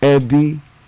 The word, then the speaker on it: Ebi: See, you got this dot in the middle. That's just the word, just that word, the accent. Ebi. – Okay. – Or I'm saying it wrong. Ebi. – Okay. – Ebi. You would say it like that.